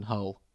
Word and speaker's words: Hull: So, but that is a sentiment shared by many people growing up in Hull, incidentally. – Yeah. – The only town known to rhyme with both dull and hell.